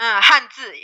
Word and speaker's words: Hanzi: And you said that there's some differences with Chinese kanji or tell me if I'm pronouncing this right, Hanzi. – Hanzi, yeah, correct.